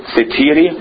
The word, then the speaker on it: satiety: Am I pronouncing that correctly, by the way? Satiety? Is it satiety? Satiety? Satiety, thank you.